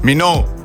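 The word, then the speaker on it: Minogue: Minou.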